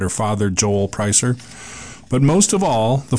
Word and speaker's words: Preisser: Leslie Pricer was killed Saturday night in LeClaire, and Pricer is spelled P-R-E-I-S-S-E-R.